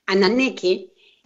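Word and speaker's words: Ananké: Y claro, es que Anaké, perdón, lo pronuncié mal hace rato, Anaké, va a ser aquella necesidad en donde algo nos falta.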